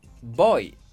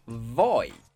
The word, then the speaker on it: voy: Por ejemplo, voy, no voy. Vamos, no vamos.